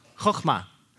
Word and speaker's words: chochmah: So the KH is the ch letter in Hebrew. Choch, right? Choch, chochmah.